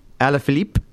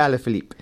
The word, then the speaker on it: Alaphilippe: So the Slovak Sagan perfectly timed his effort in the final climb to pip France's Julien, I'll pronounce this one, Alaphilippe from the Quickstep team. – Show me that one. Let me see if I can pronounce it. – Alaphilippe.